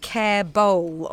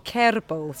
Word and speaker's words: Kerbaol: I, what's so annoying is I've written phonetically care bowl on my care bowl. – Cédrine, You've got to do the rolling R. – Kerbaol, Cédrine Kerbaol.